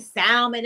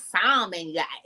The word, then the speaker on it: salmon: They're like, it ain't salmon, it's salmon. – Oh, yeah. Pronouncing the L, yeah.